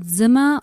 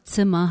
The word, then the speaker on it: Zimmer: And that is, so Zimmer, or Zimmer, how I would pronounce it in German, is a French producer, and Panama is the singer.